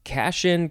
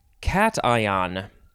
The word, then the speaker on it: cation: Next is now I would want to pronounce this, uh, you know, uh, Cashin, Cashin, uh, just with my normal knowledge of English, but it is actually cation, cation, something like that.